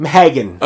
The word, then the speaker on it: Mhaegan: So the last person Jon Arryn talked to was a prostitute named Megan. She's the mother of a baby daughter. – So, hang on. We have all these names, and she's just Megan. – Well, M-H-A-E-G-A-N. – It's Megan.